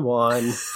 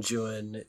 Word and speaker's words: Juan: And I know it's Don Juan. He pronounced it Juan. He knew Spanish. – Yeah. – He spoke Spanish. He read Spanish, but still his Anglicisms are there, and he rhymes it Juan. He rhymes it with things like new one.